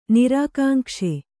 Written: ನಿರಾಕಾಂಕ್ಷೆ

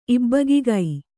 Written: ಇಬ್ಬಗಿಗೈ

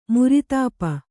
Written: ಮುರಿತಾಪ

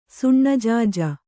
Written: ಸಣ್ಣಜಾಜಿ

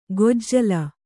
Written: ಗೋಜಲ

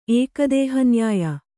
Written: ಏಕದೇಹನ್ಯಾಯ

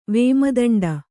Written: ವೇಮದಂಡ

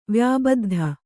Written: ವ್ಯಾಬದ್ಧ

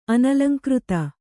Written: ಅನಲಂಕೃತ

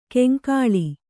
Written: ಕಂಕಳ

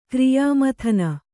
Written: ಕ್ರಿಯಾಮಥನ

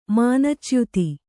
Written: ಮಾನಚ್ಯುತಿ

